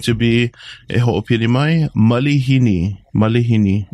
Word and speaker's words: malihini: So that's a um common I don't want to say mispronunciation, but yeah, a lot of people think there's an actual A after the the L, but it's I, so it's going to be Malihini. Malihini.